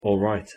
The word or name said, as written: alright